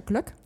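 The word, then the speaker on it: Glögg: Starker schwarzer Filterkaffee oder einen schwedischen Glöck, das nennt sich ja Glöck, ja.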